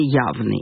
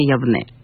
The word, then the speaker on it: Явне: И, как я вам обещала, это будет урок, в котором будем говорить о городе Явны. Или, как положено его произносить, вообще Явне.